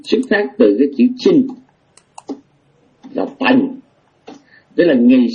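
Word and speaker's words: Qin: và họ gọi là nước Tần là trong cái tiếng Anh của Âu Mỹ nói chung, Anh Pháp Đức là đọc là chữ Chin.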